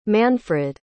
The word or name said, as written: manfred